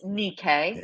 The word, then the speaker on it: Nike: But Nike. I hear you. – You mistreat your athletes. – It's pronounced Nikkei.